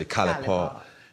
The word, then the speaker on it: College Park: The G is silent. It's really enunciated as College Park, but the people where I'm from, they just say Cali Park.